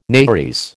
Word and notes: nares: American English

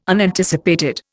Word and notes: Unanticipated: Indian

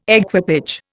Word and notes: equipage: Indian

